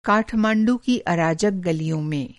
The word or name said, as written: काठमाँडू